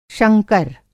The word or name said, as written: शंकर